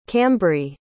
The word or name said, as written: cambree